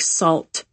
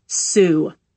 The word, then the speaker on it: Sault: Now, don't be like tourists and pronounce it like salt. It's Sioux.